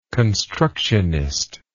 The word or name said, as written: constructionist